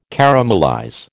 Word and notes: caramelize: American English

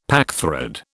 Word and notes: packthread: British English

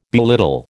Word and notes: belittle: American English

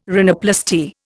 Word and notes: rhinoplasty: Indian

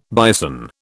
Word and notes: bison: British English

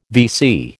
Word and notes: VC: American English

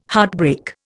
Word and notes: heartbreak: Indian

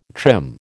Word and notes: trim: American English

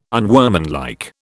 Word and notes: unworkmanlike: British English

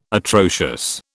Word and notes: atrocious: British English

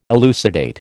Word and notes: elucidate: American English

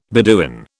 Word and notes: Beduin: British English

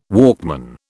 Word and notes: Walkman: British English

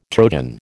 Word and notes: Trojan: American English

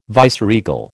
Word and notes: viceregal: American English

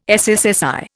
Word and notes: SSSI: Indian

